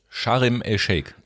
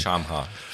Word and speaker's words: Sharm el-Sheikh: Richtig? Sharm el-Sheikh. Sharm, ich kann das nicht aussprechen. Nicht Shamha.